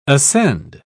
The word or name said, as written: ascend